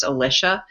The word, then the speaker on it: Alicia: My name is spelled Alicia, but it's pronounced Alicia.